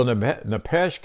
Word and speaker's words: nephesh: It's nephesh, N-E-P-H-E-S-H, and it's pronounced nephesh, F-E-S-H, and which it means a breathing creature, and it means vitality.